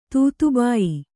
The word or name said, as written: ತೂತುಬಾಯಿ